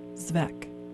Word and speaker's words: Svec: S-V-E-C. – Okay, and then you pronounce it what? – Zvek.